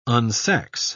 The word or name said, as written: unsex